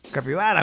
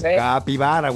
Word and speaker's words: capibara: Parece Copaibara. – Capibara, güey. – Capaibaira, ¿cómo se dicen esos Garritas? – Capibara, güey.